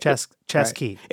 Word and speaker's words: Chesky: He said, think of chess and key. – Right. – Chess key. – It's the C and the H that mess you up.